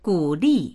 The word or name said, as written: gu3li4